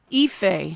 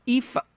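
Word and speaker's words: Aoife: Ife, A-O-I-F-E. I almost had to put out a call to Maeve. I had to throw out a lifeline to Maeve Conran to tell me how to pronounce that. Ife.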